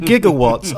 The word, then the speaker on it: gigawatts: Or is it gigawatts? I don't know. Too much Back to the Future. – It's written with a G, but it's pronounced with a soft G, which is a J.